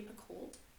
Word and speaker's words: Picoult: It's by Jodi Picoult.